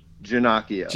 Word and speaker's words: Ginocchio: So is it – I said it was Paul Ginocchio, like Pinocchio. – Well, it's spelled the same way. So okay. It's been butchered much worse, so you did a great job. – Okay. So give us the correct pronunciation. – Ginocchio.